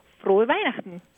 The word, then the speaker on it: Frohe Weihnachten: Frohe Weihnachten.